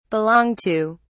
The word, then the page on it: belong to: British English